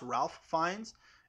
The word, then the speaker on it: Ralph Fiennes: If you don't know this, it's not pronounced Ralph Fiennes. It's Ralph Fiennes.